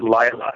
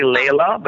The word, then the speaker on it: Laila: It spells L-A-I-L-A. In Norway, we pronounce it Lila. Here we say Layla, but it's actually Lila.